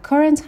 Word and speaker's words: current: و current هم به معنای فعلی هستش. استرس بخش اول کلم است.